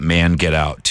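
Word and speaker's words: Mangetout: Wet Leg Manche 2 on X1039. It's okay if you want to pronounce it Man Get Out 2. They meant it both ways.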